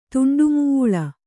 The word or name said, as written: ತುಂಡುಮುೂಳ